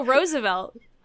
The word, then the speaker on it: Roosevelt: I mean, Teddy and Franklin both pronounced it differently, so... – Oh, I've always heard Roosevelt as, like, someone who hated him in, like, a cartoon. – Oh, no. – Pronouncing it. – Like, the two presidents both pronounced the last name differently, so... – Oh. – Both are basically... And they're related. – Yeah. – So both are pretty much acceptable.